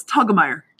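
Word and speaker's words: Telgemeier: Raina You're so close. Tegelmeyer. – Tegelmeyer.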